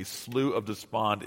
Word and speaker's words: Slough of Despond: I think it's the right way to pronounce it. I've actually asked Scotsmen, and they've told me Slough of Despond is correct.